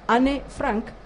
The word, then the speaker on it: Anne Frank: Vi el documental de Netflix de Anne Frank, porque así es como lo pronuncian en alemán, Anne Frank, o sea, Anne Frank o Anna Frank, y Las vidas paralelas, que está narrado por esta, ¿cómo es que se llama?